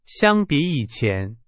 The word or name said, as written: 相比